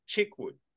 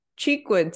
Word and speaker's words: Cheekwood: Is it Cheekwood? Chickwood? – Oh, Cheekwood, Cheekwood.